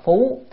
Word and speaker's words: phú: Cái chữ này đọc là phú thì là che trùm, còn đọc là phúc là lại. Đây mình đọc phú,